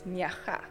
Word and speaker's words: Nyara: Yeah, and you see, you spell it N-Y-A-R-A, and I would be like Nyara. – Yes. – Sounds like a game reserve. – Yes. So it becomes an Afrikaans, the R becomes an Afrikaans G, but in the Xhosa language, yes, Nyara.